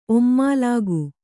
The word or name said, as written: ಒಮ್ಮಾಲಾಗು